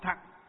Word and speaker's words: thặng: Chữ thừa ở đây á, nguyên âm chữ Hán nó họ đọc là chữ thặng.